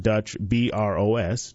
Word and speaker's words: Dutch Bros: Dutch Brothers, really they just spell it Dutch, B-R-O-S, Dutch Bros, plans to build a 950-square-foot building adjacent to the Fifth Third Bank branch off Mooresville Parkway near Trader Joe's.